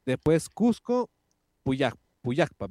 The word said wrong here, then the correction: Pucallpa: ¿verdad? Así se pronuncia. – Pucallpa, Pucallpa. – Pucallpa y Callao.